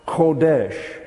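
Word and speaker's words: Kodesh (חודש): It looks like this. C-H-O-D-H-E-S-H. Now that is pronounced Kodesh.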